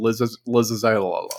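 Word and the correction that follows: Lae'zel: I have to see Lizelle. – Lizelle.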